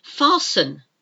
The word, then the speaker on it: fasten: And it is a silent T. It's not fasten. That's how it looks. F-A-S-T-E-N. All one word.